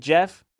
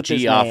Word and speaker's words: Geoff: I said Jeff. Nick, the way you're looking at me, I bet you think it's spelled J-E-F-F. – Fucking Jeff has a walk in the park, J-E-F-F. – I wrong, bitch. G-E-O-F-F.